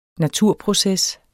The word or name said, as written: naturproces